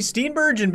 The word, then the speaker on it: Steenburgen: And then you got how do you pronounce her name? Mary Steen. – Mary Steenburgen, baby.